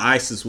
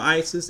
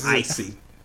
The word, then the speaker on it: Isis: And my favorite thing about Isis, if you listen to Jadakiss's rap on that song, he does not know how to pronounce that group's name. He keeps calling them Icy.